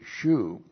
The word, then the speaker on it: shub: S-H long U-B, shub.